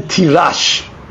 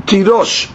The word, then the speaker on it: Tirosh: One time the Torah uses the word Tirosh without a vav, so it can be read Tirash. Haser vav. Right? Vekarenan Tirosh. But you read it Tirosh.